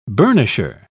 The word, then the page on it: burnisher: British English